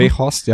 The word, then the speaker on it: Weghorst: Und das, obwohl sie vorne mit, jetzt musst du mir kurz helfen, Wout Weghorst, wird er so ausgesprochen? – Weghorst, ja. – Ja, genau. Also